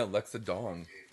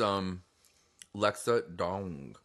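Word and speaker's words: Lexa Doig: Is that how you say her name? – Yeah, Lexa Dong. – Like as in dong? I know it's D-O-I-G. – No, it's Lexa Dong.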